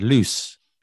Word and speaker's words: loose: Lose has a long vowel sound, lose, and it's only got one vowel, well, one O. And loose has shorter vowel sound, and it's got two O's.